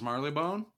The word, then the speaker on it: Marylebone: Yeah, I think it's just pronounced Marylebone. – Marylebone? Okay, yeah, that would make sense. – Yeah, it's spelled weird, but right.